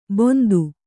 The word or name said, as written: ಬೊಂದು